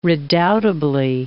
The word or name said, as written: redoubtably